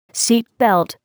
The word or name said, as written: seat belt